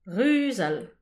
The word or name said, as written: ruzal